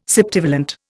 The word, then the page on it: septivalent: Indian